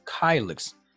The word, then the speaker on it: kylix: Kylix. How do I pronounce this thing? So K-Y-L-I-X. Pronunciation. Help me out here, magic Google machine. Kylix. Okay. Got it right the first time.